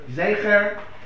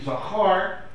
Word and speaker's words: Zecher: because apparently Yoav ben Tzruya's Rebbe mispronounced the word Zecher, and therefore it was interpreted to be Zachar, males, and that was a mistake.